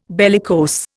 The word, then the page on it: bellicose: Indian